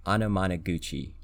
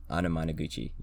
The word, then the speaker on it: Anamanaguchi: Um, the album of the week is USA by Anamana. I'm gonna pronounce this wrong. Anamanaguchi. Anamanaguchi. Yes. So the album of this week is USA um, by Anamanaguchi.